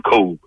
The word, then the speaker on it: Kobe: And anybody close to him, we don't pronounce the E in his name. We just call him Kobe.